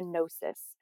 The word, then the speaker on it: gnosis: And really the pathway to feminine embodiment is through the gnosis, and that's spelled with a G, G-N-O-S-I-S, gnosis, that's your body wisdom.